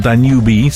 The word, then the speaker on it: Danube: So look, after the huge success of its Harrow-based office, Danube Properties, or Danube, some people like to pronounce the E, Danube Properties Dubai is delighted to announce the opening of their second London office in Knightsbridge today, this Saturday.